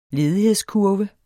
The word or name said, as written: ledighedskurve